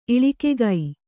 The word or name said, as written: ಇಳಿಕೆಗೈ